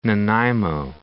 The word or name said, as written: Nanaimo